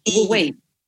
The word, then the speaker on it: Wu Wei: ¿Lo pronuncié bien o no? Escuela Wu Wei. – Sí, está correcto, Escuela Wu Wei de Artes Energéticas y Acupuntura.